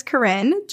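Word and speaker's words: Koren: My name is Corinne, just spelt different. – Oh. – It's K-O-R-E-N.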